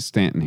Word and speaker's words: Staunton: Like, how close are you to Staunton? – So they pronounce it Stanton here because they don't know how the English language works. Yeah. They're wrong. I lost that battle.